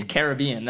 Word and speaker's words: Caribbean: It's Caribbean. That's how you pronounce the word.